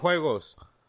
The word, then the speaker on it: juegos: Well, I just put juegos.